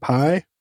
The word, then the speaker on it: Pye: Art by, I don't know how to say it, Pypar, P-Y-E, how about Pi? – Yeah, yeah.